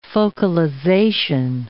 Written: focalization

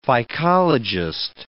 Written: phycologist

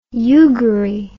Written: eugarie

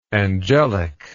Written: angelicalness

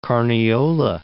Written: Carniola

